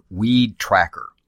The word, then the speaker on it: WeedTraQR: Eric is with a Seattle-based company called Weed Tracker. That's spelled W-E-E-D-T-R-A-Q-R.